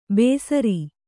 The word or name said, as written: ಬೇಸ್ರಿ